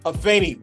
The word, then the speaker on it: Afeni: That's right, Afeni with the A, all right? You see it with an E, but you pronounce it with an A.